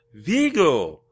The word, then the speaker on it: Viggo: Oh, Viggo!